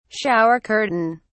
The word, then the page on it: shower curtain: British English